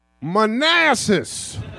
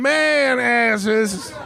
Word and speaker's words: Manassas: Also, gentlemen, just so you know, the town in which this battle will be fought is pronounced Manassas. I know a lot of y'all been having a lot of fun telling people we gonna be all up in man asses.